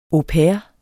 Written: au pair

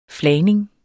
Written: flagning

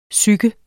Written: PSYKET

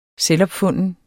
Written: selvopfunden

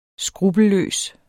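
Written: skruppelløs

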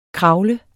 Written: KRAVLES

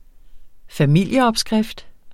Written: familieopskrift

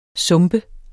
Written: sumpe

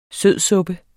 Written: sødsuppe

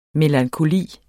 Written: MELANKOLIEN

